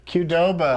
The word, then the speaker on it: Qdoba: I say Qdoba. – I don't know if the internet will explain to you how to pronounce the name. – Qdoba. – How do you say Subway? – There it is. – What is it? – Phonetic. – Qdoba.